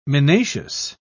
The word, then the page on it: minacious: British English